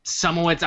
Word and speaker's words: Szumowicz: That's just my full name, Austin Sumowitz.